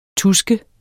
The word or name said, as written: TUSKENDE